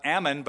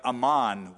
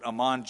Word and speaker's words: Amman: There's a place in Jordan called, not Ammon, but Amman, we pronounce it, Amman, Jordan.